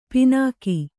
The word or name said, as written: ಪಣ್ಣಿಕೆ